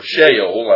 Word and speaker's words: Sheol: As you go through, you will see that the Hebrew word that is rendered hell in the English is the Hebrew word Sheol, or Sheol, I've heard it pronounced, S-H-E-O-L.